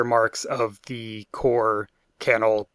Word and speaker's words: Cannell: And the the script for this one is by Stephen Cannell, who we learned is pronounced Cannell, not Cannell, thanks to a video that Epi sent me. So apologies for our previous episodes where uh, I mispronounced that name. It is in fact Cannell.